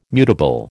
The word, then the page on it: mutable: American English